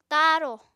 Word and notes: taarò: Apatani

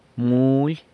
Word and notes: muly: sanlucasquiavini

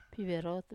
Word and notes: piverotu: wamesa

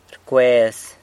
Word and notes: rcwez: sanlucasquiavini

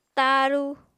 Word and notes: tañru: apatani

